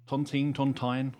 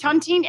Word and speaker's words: Tontine: And this is the Tontine, is that right? – Tontine. I never know how to pronounce Tontine. Tontine. And where is the Tontine? – In Ironbridge.